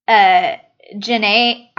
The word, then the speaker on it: Jhené: Magic Hour by Jhené Aiko. It's J-H-E-N-E with an S. No, you said it right.